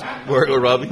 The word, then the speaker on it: Margot Robbie: Margot Robbie. – Margot Robbie. That's the way to pronounce that.